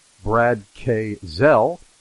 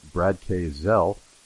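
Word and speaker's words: Brad K. Gsell: And I'm very delighted to have back as a returning guest, Brad K. Zell. And if you're looking up his name on the internet, it's actually spelled G-S-E-L-L, and the G is silent, Brad K. Zell.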